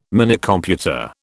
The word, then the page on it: Minicomputer: British English